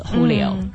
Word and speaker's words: Julio: 对还有这边呢这朋友接着说老友记里面呢那只比较可爱的小猫 Phoebe 呢说是他妈妈转世的那一只呢名字是叫 Julio 他以为呢应该是写成 J-U-L-I-O 结果是 H-U-L-I-O 这个没有弄懂这我觉得像一个西班牙的名字 Julio